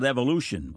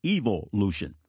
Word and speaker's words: evolution: The God of the world's academics is the blasphemy called evolution, or as the English pronounce it, evolution.